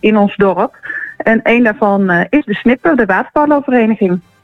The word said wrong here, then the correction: De Snippen: De Snipper of De Snippen? – Snippen. – Snippen. Ja. Als één vogel De Snip en dan de meervoud De Snippen.